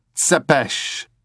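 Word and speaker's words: Țepeș: Tepesh, professional exsanguinator. Remember, it is pronounced Tsepesh.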